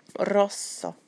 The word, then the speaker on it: rosso: So when there's a double, like in prosecco, there's almost a little gap between the two C's. So it's prosecco, rosso. Any time that you see a double letter, make sure you pronounce both letters.